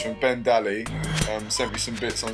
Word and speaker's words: Dally: It's Ben, it's either Daly or Dally. – Dally, it's Ben Dally. – You reckon it's Ben Dally? – It is, it's D-A-L-L-Y, which is no I, therefore pronounced Dally.